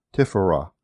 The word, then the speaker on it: tipharah: This Hebrew word is spelled T-I-P-H-A-R-A-H, tiphora.